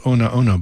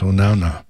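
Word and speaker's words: Onaona: And that was Ku'uipo Onauna. Sometimes it's called Ona Ona, but it's the more modern, proper pronunciation is Onauna, which means pleasantly sweet.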